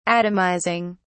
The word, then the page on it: atomizing: British English